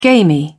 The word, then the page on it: gamy: British English